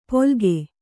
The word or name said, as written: ಪೊಲ್ಗೆ